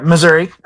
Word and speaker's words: Missouri: Am I saying that right? Missouri? – Yes. Yeah, Missouri.